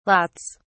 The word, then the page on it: lots: British English